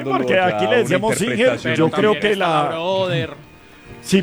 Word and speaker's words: Singer: Y la pronunciación oficial debía ser Singer.